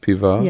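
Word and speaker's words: piva (пиво): So it would be spelled pivo, but pronounced piva. – Yes. When the ending is not stressed, you never know whether it's spelled a or o. Yes. And this is a neuter noun spelled with o. But the o is pronounced as neutral sound or a in the end. So it's piva.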